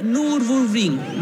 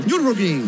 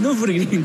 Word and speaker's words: Nürburgring: corrió la Copa 2, como decía, la Coupe 2, como se dice, y ganó las 24 horas de Nürburgring. Lo pronuncié muy mal. – Nürburgring. – Nürburgring, claro.